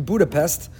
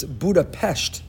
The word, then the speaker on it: Budapest: This past Friday afternoon, I was sitting in a restaurant in Budapest. That's the incorrect way to pronounce it. Now as an expert, I'll tell you, it's Budapest.